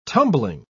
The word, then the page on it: tumbling: British English